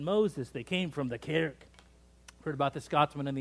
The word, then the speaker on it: kirk: Could you say that with me? The kirk. And I want you to do it again, and I want you to say it like you're clearing your throat. Kirk.